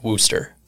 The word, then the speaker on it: Worcester: It's spelled Worcester, but I actually believe it's pronounced Wooster.